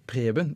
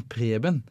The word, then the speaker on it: Preben: Eh, som Preben Nei, Preben. Ikke Ruben, men Preben.